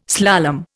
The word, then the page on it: slalom: Indian